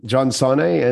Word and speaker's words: John Sanei: I don't know how I've struck it so lucky, but there's only one John Sanei. There is no other John Sanei. I have searched. – Oh, I've been saying it wrong. I'm so sorry. Sanei. – Don't worry. No problem. John Sanei.